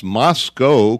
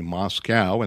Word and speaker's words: Moscow: pronounced Moscow correctly, something that Rachel Maddow and Lawrence O'Donnell do not do when they're on MSNBC. They say Moscow, and there is no cow in Moscow.